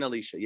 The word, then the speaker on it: Elisha: You pronounce him Elisha? – Or Elias and Elisaeus, if you're following the Septuagint, the Greek text. Anyways, but Elijah and Elisha, yes.